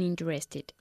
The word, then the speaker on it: interested: Henry tried to convince me, but I wasn't interested. – Okay, interested. No se pronuncia la primera E. Okay, interested. – Interested. – Sí, perdón. El énfasis recae en la primera sílaba, okay?